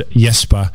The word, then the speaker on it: Jespa: They're called Yespa. It's spelled with the J.